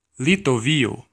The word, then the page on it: Litovio: /li.tɔ.ˈvi.ɔ/